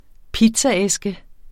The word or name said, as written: pizzaæske